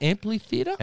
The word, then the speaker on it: amphitheater: Did I say that right? Amphitheater? – Amphitheater. – Amphitheater. – Yeah. – What am I putting in an extra L? – Well, putting a bit of mustard on it. Amplifier. Amphitheater.